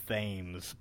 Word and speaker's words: Thames: It is spelled Thames, but they call it the Thames.